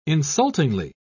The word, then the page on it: insultingly: British English